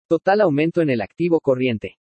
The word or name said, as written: TOTAL AUMENTO EN EL ACTIVO CORRIENTE